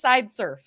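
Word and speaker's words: Sideserf: It's funny that you mentioned the spelling, but it is Sidesurf.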